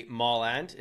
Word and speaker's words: Mallard: My name is Randy Mollard, is how it's pronounced.